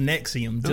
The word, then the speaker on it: NXIVM: I mean, I saw in multiple articles it is pronounced NXIVM, just like the antacid.